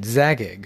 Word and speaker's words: Zagig: But Gary Gygax's original Greyhawk dungeon, the 13th level, was the home of the mad wizard Zagig, who, that's Gygax pronounced backwards.